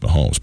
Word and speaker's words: Mahomes: I was like, how do you say that? And then it's like, oh, well, it's just Mahomes.